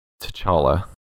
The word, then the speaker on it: T'Challa: T'Challa.